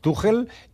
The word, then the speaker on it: Tuchel: Tuchel, que no m'he pronunciat bé, Tuchel, és que el proper partit d'Anglaterra el titular sigui Rashford.